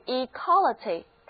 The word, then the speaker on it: equality: 这里最后要强调就是 equality，它的重音已经挪到第二个音节了，和 equal 不但发音不同，而且重音也不同，读的时候一定要仔细哦。